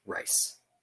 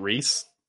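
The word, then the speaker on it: Rhys: It's always weird that they actually pronounce it as Rice. – It's not Reese. – Not Reese. He's from the Isles, and he is Rice McKee.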